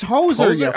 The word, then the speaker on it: hoser: Like you hooser? – That's not how you say it. – Hooser. – Hey, yo, hoser, yo, yo. – It's hoser. Hoser? – I'm not trying to say hoser, you cocksucker.